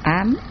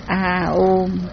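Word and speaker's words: a ôm (ॐ): Mà Đức Thích Ca thì có cái chữ ám, chúng mình đọc là ám, mà thật ra cái câu ấy mình phải đọc là a ôm, nghĩa là nó phải dài mà nó phải thật mạnh.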